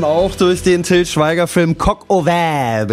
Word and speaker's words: Coq au Vin: Mais mon Dieu, il faut prononcer Coq au Vin.